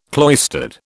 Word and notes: cloistered: British English

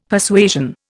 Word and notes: Persuasion: Indian

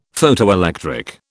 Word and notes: Photoelectric: British English

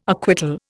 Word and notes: acquittal: Indian

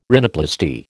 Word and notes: rhinoplasty: American English

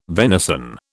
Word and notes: venison: British English

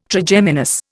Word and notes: Trigeminus: Indian

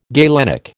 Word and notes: galenic: American English

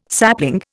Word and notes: sapling: Indian

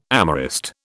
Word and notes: amorist: British English

